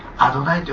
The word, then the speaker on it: アドナイ: で、それをアドナイと読むようにしたんです。